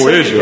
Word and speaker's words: poejo: Uma outra coisa aqui também que eu experimentei é o chá de poejo. – É, eu, eu, falei certo? É, isso poejo.